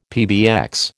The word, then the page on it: PBX: American English